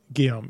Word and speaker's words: Guillaume: So Robert Guillaume. We got James... – Is that how you pronounce it? – Guillaume, yeah.